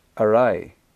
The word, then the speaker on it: awry: I think in English, while you've got the word A-W-R-Y, right? I don't know how most people would probably pronounce that wrong. That things have gone A-W-R-Y, right? It's actually pronounced Arai. Now, if you go to Malaysia, right? In this country, we write postcode as P-O-S-T.